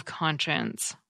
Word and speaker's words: conscience: Here's how I remember the spelling of conscience. The word conscience ends with science, science, even though it's not pronounced that way.